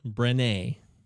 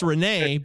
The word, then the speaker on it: Brené: Oh, B-R-E-N-E, Brené. Ooh. So it's Brené, but add a B at the beginning.